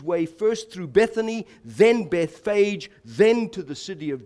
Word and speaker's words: Bethphage: I pronounce it Bethphage, by the way, because the Greek text says Betphago. So the F sound is in the Greek text. That's why I say Bethphage as opposed to Bethpage, if you are used to that.